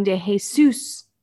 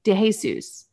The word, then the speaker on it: DeJesus: It's Robin DeJesus. DeJesus.